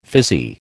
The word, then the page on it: fizzy: American English